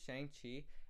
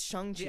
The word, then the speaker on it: Shang-Chi: It's not Shang... I remember clearly because I was calling it Shang-Chi... And then the actor went on Twitter and said Shang-Chi. – I must have mixed up the two pronunciations. – But it's Shang-Chi for sure.